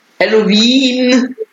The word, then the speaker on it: Halloween: Halloween, wie Wien, wie die Hauptstadt. Hallo W-E-E-N, ne?